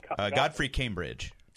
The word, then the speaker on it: Godfrey Cambridge: Godfrey Cambridge, or how do you pronounce his name? – Godfrey Cambridge.